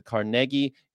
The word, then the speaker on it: Carnegie: And you might be asking, why do you say Carnegie? Well, that's because that's how he pronounced his name. He pronounced it Carnegie. You usually hear Carnegie, except when you hear Carnegie Hall, because people like to say Carnegie Hall. But his real name was Carnegie.